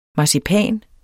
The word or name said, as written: marcipan